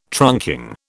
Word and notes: trunking: British English

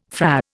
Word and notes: Fra: Indian

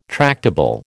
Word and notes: tractable: American English

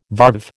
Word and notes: varve: American English

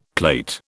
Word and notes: plate: British English

